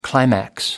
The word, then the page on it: climax: American English